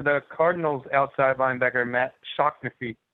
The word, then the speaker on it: Shaughnessy: And similarly, with people called Shaughnessy, if you have any guy called Shaughnessy, his actual name here in Ireland is Shocknessy.